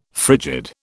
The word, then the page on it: frigid: British English